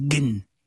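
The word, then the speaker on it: gen (جن): C'est-à-dire que on utilise le mot, enfin, en arabe égyptien, on dit pas djinn, on dit gen. – Ouais. – Parce qu'on prononce pas le dje, on dit ge à la place.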